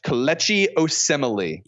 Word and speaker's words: Kelechi Osemele: I'm going to say his name wrong forever. I usually say my offensive lineman's name wrong forever. They're just the guy blocking my quarterback. – Kelechi Osemele. – Okay, there you go. With the pronunciation for me and for all of you.